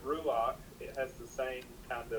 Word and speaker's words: ruach: This is just one of those unusual rules that we find in the first few chapters of the first book, that when you have a chait with a patak under it, it's pronounced ach, like ruach, noach, correct.